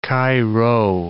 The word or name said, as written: Chi-Rho